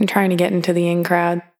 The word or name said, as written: The In-Crowd